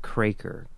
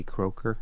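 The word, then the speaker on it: Kroeker: And then one of the directors on the show was Alan, I believe he pronounced it Kraker, K-R-O-E-K-E-R. I think he pronounces it Kraker, maybe Croker. I think it's Kraker.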